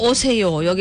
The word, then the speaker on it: 오세요: 그리고 아침으로 오세요. 여기서 또 오세요라고 하지 말고 요는 요로. – 요. – 예. 장백에 빛나는 아침으로 오세요. 요. 예. 아니면 요로 들립니다.